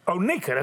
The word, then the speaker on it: Oneka: McClellan, but it's Oneka. I said it right. That's right. Exactly.